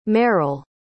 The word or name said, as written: meryle